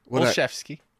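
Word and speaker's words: Olszewski: Close. Olchevsky. – Olchevsky. You know what? I should ask you. You're the Polish. – I wrote it right there. – I can't pronounce. You know me. I'm dumb. – No. Olchevsky. – Olchevsky. – There you go. You said chief.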